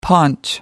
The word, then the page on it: paunch: American English